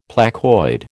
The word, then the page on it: placoid: American English